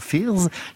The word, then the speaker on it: Fyrs: Fierce, drôle de nom avec un Y. Quand on prononce, on entend Fierce, les craintes en anglais.